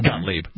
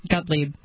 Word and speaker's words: Gottlieb: How do you pronounce that? – Gottlieb. – Gottlieb.